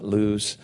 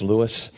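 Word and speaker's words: Lewes: It's like kind of calm right near the Lewis Cape May Ferry. But it's really, and it is pronounced Lewis. Just ask the locals, not Lewis. It's Lewis.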